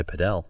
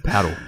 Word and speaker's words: padel: You can go and play padel. – Padel. Emphasize the L. Paddle. Paddle? – Well, padel. – I've been told to padel. Pronounce it correctly.